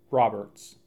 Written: Roberts

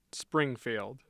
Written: Springfield

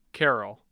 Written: carroll

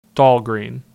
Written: Dahlgren

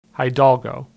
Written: Hidalgo

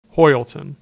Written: Hoyleton

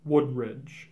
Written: Woodridge

